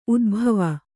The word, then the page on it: ಉದ್ಭಾವ: Kannada